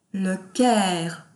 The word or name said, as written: Le Caire